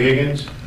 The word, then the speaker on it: Higgins: Yes, Stephen Kay from the District Attorney's Office in Los Angeles, and my last name is spelled K-A-Y. – Stephen Kay from his mother. – Okay. – Gary Higgins, H-I-G-G-I-N-S, classification and parole representative. – Laura Flemate, F-L-E-M-A-T-E, office assistant.